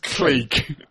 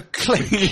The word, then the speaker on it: clique: Yeah, it's a clique.